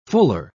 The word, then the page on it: fuller: British English